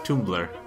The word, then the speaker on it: Tumblr: It's pronounced Tumblr.